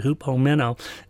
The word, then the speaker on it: hupomeno: When the Greek word hupomeno, that's how I pronounce it, hupomeno, is translated into English, it's translated with words like patience, endurance, and perseverance.